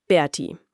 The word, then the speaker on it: Berti: Wie spricht man das aus? – Ganz einfach Berti. Das T und die 1 am Ende, die stehen für Typ 1 Diabetes.